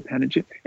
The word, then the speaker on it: panegyric: I always say panegyric. – You're probably right, as listeners have lovingly noticed over time. Yeah. I mispronounce a lot of things, but hey, take it as it is. – Well, it's Panegyric. Panegyric. Anyways, I'm going for panegyric.